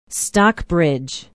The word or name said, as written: stockbridge